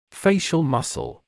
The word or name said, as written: facial muscle